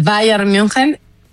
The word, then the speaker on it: Bayern München: A ver, el América está nominado junto con el Barça femenil, junto con el Bayern München. ¿Lo dije bien? – Lo hiciste precioso.